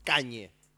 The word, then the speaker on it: Kanye: Aparte, Kanye se pronuncia como se escribe, boludo. Kanye.